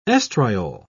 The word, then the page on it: oestriol: British English